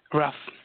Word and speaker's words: Raf: Raf. – How do you spell it? – Raf. It's R-A-F.